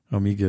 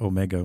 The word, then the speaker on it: Omega: Oh, S-E-I. – Yeah. Seiko. – Seiko. – And same thing with Omega. Omega is pronounced like that as opposed to Omega, which is how people tend to pronounce it in North America. But the original Greek pronunciation is Omega.